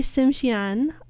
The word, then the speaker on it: Tsimshian: The singer only sings in Smayak, which is the language of the Tsimshian, sorry, that's not how you pronounce it. I'm going to pronounce it correctly because I have it pulled up here, of the Tsimshian language and people.